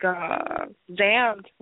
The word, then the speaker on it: Xamd: But, like, Zammed, which is spelled X-A-M-D, it's a pretty complicated story.